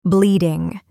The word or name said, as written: bleeding